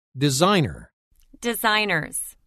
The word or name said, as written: Designer – Designers